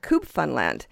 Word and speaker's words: Cubfunland: We've been enjoying their music since their first album and all the ones since, including their latest, their fifth, and that's called Cubfunland. Now, that sort of rhymes with Newfoundland,